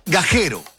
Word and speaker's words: Gajero: Y Zacarías Gallero o Gaguero, ¿cómo se pronuncia doble G? Gaguero, Gajero es, ¿no es cierto? Porque no hay U, ¿cierto? Gajero, con G, por si acaso, no vayan a mal escuchar a algunos.